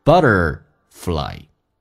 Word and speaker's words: butterfly: 而且 t 浊化成 d 这个发音，在美式发音 butterfly， but magically It turns into a butterfly, a better version that amazes the world.